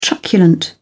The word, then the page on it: truculent: British English